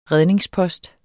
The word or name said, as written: redningspost